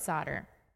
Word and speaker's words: solder: I feel like I would say solder, but then I'm like, every time I read it, I'm like, but there's an L.